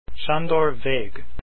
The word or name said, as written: Vegh